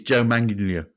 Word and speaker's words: Manganiello: So, yeah, so, and if you want a pronunciation check there, it is Joe Manganiello.